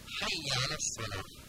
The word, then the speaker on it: حی علی الصلاة: اینجا بازم حی جیمی داریم که گفتیم باید به حالت عربی صحیح تلفظ کنیم و یا مشدد هم داریم، حی نیست حی. و نکته سوم این که یه فتحه داره، حی درست نیست، حی درست، حی علی الصلاة.